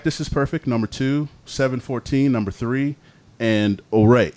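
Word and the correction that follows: Ouray: Am I saying that right? Oray? Is that the... – It's pronounced Uray.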